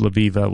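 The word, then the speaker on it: Levieva: And I think they're saying it's pronounced Laviva, which is fantastic.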